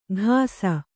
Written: ಘಾಸ

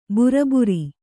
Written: ಬುರಬುರಿ

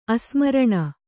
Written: ಅಸ್ಮರಣ

